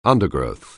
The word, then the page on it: undergrowth: anglais